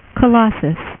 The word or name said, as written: colossus